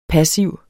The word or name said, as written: PASSIVERNE